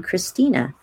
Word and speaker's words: Christina: Christina, spelled with a CH.